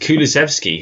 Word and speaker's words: Kulusevski: With Kulusevski, who it's weird to think about the actual football. – You said his name right.